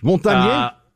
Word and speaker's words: Montagnier: Go, just lean into the French. Montagnier?